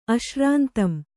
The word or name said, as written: ಅಶ್ರಾಂತಂ